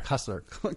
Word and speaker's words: Cussler: Cussler.